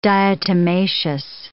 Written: diatomaceous

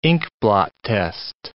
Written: inkblot test

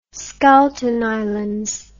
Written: schouten islands

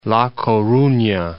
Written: La Coruna

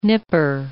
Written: nipper

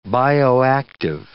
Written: bioactive

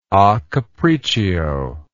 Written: acapriccio

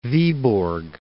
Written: Vyborg

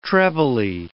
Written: trebly

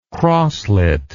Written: crosslet

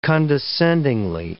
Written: condescendingly

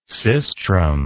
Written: sistrum

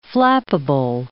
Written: flappable

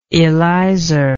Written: elisor

